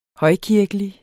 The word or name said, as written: højkirkelig